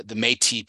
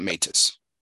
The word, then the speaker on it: Métis: It's the Métis people, by the way, not Métis.